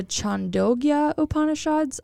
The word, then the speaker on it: Chandogya Upanishads: So it's an old Vedic story about prana in the Chango. What? Oh, I practiced in it and I still messed it up. It's the Chandogya Upanishads. I want to believe I said that right.